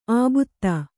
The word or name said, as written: ಆಬುತ್ತ